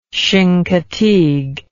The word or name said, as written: chincoteague